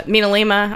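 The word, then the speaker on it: MinaLima: But we went to the house in Miralima where the, Mina Lima, I always mispronounce it, where like the people that designed...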